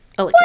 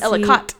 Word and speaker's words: Ellicott: Yeah, it's E-L-L-I-C-O-T-T. – Ellicott. – What? E-L-L-I-C-O-T. People pronounce it Ellicott, but it's Ellicott.